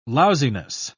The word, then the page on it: lousiness: British English